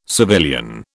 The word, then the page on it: civilian: British English